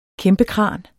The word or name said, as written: kæmpekran